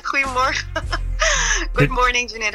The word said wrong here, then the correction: Guten Morgen: Did I say that right? – Well, it's Guten Morgen, but you're almost there. – Guten Morgen. Is that Yeah, that's it. – I'm going to learn some Dutch from you this morning, if that's possible.